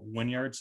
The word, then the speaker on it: Wynyard: Appreciate your time, and you're coming to us from Wynyard, Saskatchewan.